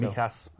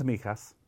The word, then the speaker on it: Mijas: How do you spell it? – M-I-J-A-S. – Mijas, yeah.